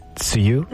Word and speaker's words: tsuyu: Very sorry if I butcher it, but you said it's tsuyu? – There you go. Yes.